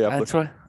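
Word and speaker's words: Eppler: Yeah, Billy Epler.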